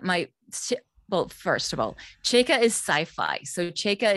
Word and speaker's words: Chaika: So this is, uh, make sure I'm saying it right now, Chaika? – Yes, beautiful.